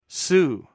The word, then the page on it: su: greek